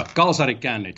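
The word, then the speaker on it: kalsarikännit: Yeah, kalsarikannat, just for your listeners' benefits, that's the authentic pronunciation here.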